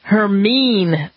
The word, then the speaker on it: Hermine: It is a hurricane that I've mispronounced actually a few times. It's Hurricane Hermine.